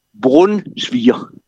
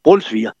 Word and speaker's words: Brunsviger: Men så tror jeg, det er bud nummer 7, det er nemlig, det udtales Brunsviger. – Ja. – Med å nærmest. Nærmest med å, ikke? Brunsviger.